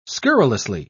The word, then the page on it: scurrilously: British English